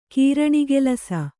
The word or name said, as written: ಕೀರಣಿಗೆಲಸ